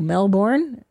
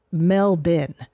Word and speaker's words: Melbourne: And I go, Melbourne? She goes, no, it's pronounced Melbourne.